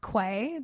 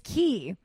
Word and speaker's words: Quay: But I have 6 more pairs now from Key, which everyone thinks that it's pronounced Quay, the Australian sunglass brand. – Oh, is it Key? – Brand. It's Key. – Q-U-A-E? – Q-U-A-Y. – Or what did I just say? – Q-U-A-E? – Yeah, it's a Y. I don't know why I added it. So it looks like Quay, but it's pronounced Key.